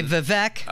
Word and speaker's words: Vivek: He said it rhymes with cake. I have heard newscasters and say Vivek.